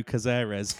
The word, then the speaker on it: Cazares: Cazares. – It's a potato Dino Cazares. – Yeah. It's actually pretty good, that. We'll put that on the Instagram, and you guys can enjoy the potato Dino Cazares.